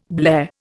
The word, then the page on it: blare: Indian